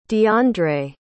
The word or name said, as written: deiondre